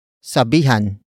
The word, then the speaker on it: sabihan: Word for word it's, sabihan ninyo si Cynthia.